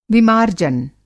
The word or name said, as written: विमार्जन